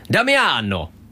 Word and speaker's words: Damiano: It's Demiano again, or was it Damiano, maybe? You did pronounce the name correct last time. Read it like it's an Italian name. You know how, right? Damiano!